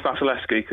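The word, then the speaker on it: Wasilewski: The new centre-back pairing of Wes Morgan and Wesolowski will be able to correct me on the pronunciation of that, because he writes about him and spells his name a lot more than I do in my job. So it's Vasilevsky, because Nigel corrected me.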